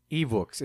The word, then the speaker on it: Evox: Evox, is that how it's pronounced? – Yeah. – That sounds like a fucking Mexican accent. – That's what it is. It's a Spanish podcast site.